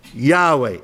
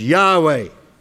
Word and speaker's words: Yahweh: they show this name is and has to be the name that is pronounced Yahweh. It can't be anything else. It is Yahweh.